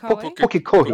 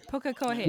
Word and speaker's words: Pukekohe: Apparently, they're from Pukekohe. Did I pronounce that properly? – Not bad. Pukekohe. – Okay, thank you. – Pukekohe.